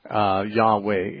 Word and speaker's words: Yahweh: It seems to me that since the Hebrew language doesn't have a J sound, Yahweh is, or Yahweh or something very much like that is the correct pronunciation.